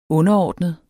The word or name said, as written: underordnet